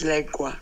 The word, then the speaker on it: lengua: But how it's correctly pronounced is lengua with an E, lengua. En qué lengua?